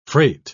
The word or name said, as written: freight